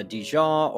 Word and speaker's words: DeJean: Is it uh, Dijon, or how would you pronounce that? – My name is a is like much of French and, and involving Louisiana, Gary. I'm my name is pronounced Dijon in Louisiana.